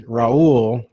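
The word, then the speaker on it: Raúl: There's like a short 2-minute video where he pronounces my name without rolling the R, and it's adorable. – Actually, yeah, one little detail for people, since my name is Raul, right? Yeah, and I grew up only, for the first few years of my life, only hearing it pronounced properly in Spanish.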